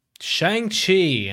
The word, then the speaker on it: Shang-Chi: I keep saying Shang. What the hell's my problem? Shang Chi. That's better, right? Why you laughing? That's not. I said it right that time.